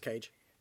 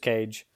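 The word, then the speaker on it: KAGE: I, I swear I always stumble on pronouncing this one, but it, it's spelled K A G E. I can't remember. It's just cage. – Just cage.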